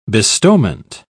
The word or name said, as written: bestowment